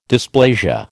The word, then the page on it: dysplasia: American English